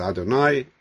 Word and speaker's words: Adonai: the Lord, Yud-Kei Vav-Kei, again, it's this thing of, we're pronouncing it as Adonai.